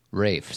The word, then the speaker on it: Ralph: Rafe, sorry. Yeah. Even though it's spelled Ralph? – Correct.